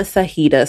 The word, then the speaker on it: Saheida: Make sure you're following me on Instagram, Brianna Sahida. So that's Brianna dot my middle name. So I'll spell it S-A-H-E-I-D-A.